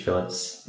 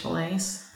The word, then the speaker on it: fillets: So right now we have some fish fillets thawing, which I'll cook up after this podcast. – Mahi-mahi. – For Americans, that's fish fillets. – Fillets. Sorry, I'm using my Australian pronunciation.